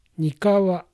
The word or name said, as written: Nikawa